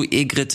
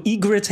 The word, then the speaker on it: Egret: Taito Egrid 2 Mini? Taito Egrid hätte ich jetzt gesagt.